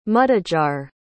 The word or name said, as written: mudejar